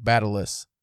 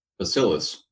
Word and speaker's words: bacillus: The guy in the video, I swear, said T, like Batalus. – Maybe. Wait, how do you spell it? – B-A-C-I-L-L-U-S. – Bacillus. – Bacillus. But the guy definitely with the French accent said it with the T. And I was like, that doesn't sound right.